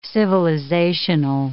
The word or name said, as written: civilizational